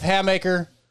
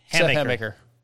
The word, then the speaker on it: Hammaker: That was Seth Hammaker, even though can't say it right. – Hammaker. – Yeah, that's close. – What I've been saying. – It still sort of almost has an ass sound to it, but it is your southern stuff.